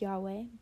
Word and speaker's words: Yahweh: And instead, they write it with a Hebrew equivalent of the word, the letters YHWH, which is pronounced Yahweh, but traditional Jews do not pronounce it.